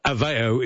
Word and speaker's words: Aveo: Pron Aveo is pronounced Av as in AO.